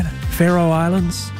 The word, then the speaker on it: Faroe Islands: Am I saying that right? Faroe Islands? Cam's nodding. Cam in the control room is nodding.